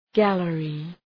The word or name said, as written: gallery